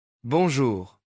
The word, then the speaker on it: bonjour: Bonjour。 – 两个发音要点。第一，这个词的尾巴是鼻音，气从鼻子放出去，别读成清楚的 N。第二，后半部分里那个颤音在喉咙后部轻轻摩擦，跟德语的小舌音很像。